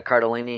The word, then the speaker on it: Cardellini: Yeah, Linda Cardellini, I think, yeah.